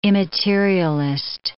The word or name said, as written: immaterialist